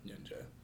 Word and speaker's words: Nyanja: But just about everybody in Lusaka will know Nyanja. – Okay. It sounds like you're saying ninja. – Yeah. It's like N-Y-A-N-G-E. Nyanja.